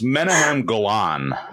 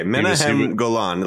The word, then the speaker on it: Menahem Golan: It's Menahem Golan. – Okay, good. Okay, Menahem Golan, like an Israeli.